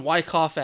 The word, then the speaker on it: Wyckoff: So we watched a match from Uncanny Attractions, Drags and Dropkicks, A Nightmare on Wyke. Oh. I always mess up the street name because it's spelled awkwardly. A Nightmare on Wyckoff Avenue. There we go.